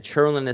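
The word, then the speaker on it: Tjernlund: If you Google Andrew Churnland, you can find my consulting situation. And Churnland is spelled T as in Tom, J as in Joseph, ERNLUND.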